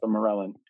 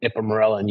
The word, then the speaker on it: Ipamorelin: So if you're using a blend, like for example, there's the blend I use, which is the CJC 1295 and the Ipamorelin. I'm not sure how to pronounce it. – Ipamorelin, correct. – Ipamorelin, yeah.